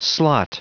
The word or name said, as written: slot